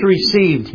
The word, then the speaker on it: received: But as many as received Him, underline the word received and put emphasis on the ED. As many as received Him,